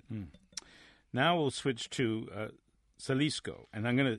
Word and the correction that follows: Xalisco: It's Jalisco. – Jalisco, sorry. – Pronounced with an H.